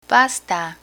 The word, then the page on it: pasta: Spanish